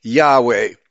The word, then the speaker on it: Yahweh: The name was pronounced Yahweh. It was all in German, would be Yahweh. The W is pronounced like a V, as it was in Hebrew.